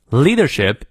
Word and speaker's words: leadership: Leadership is inspiring extraordinary people to step up and serve their country. 啊，你看 and 发的很轻，直直接发个 n 的发音就可以了，and serve their country。再来一遍，Leadership is inspiring extraordinary people to step up and serve their country。英式发音元音后的 r 不念，leadership leadership 那个 r 不念，leadership is inspiring inspiring r 还是要念的，因为在元音前。